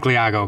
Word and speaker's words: Leago: It's Mike Leago, by the way.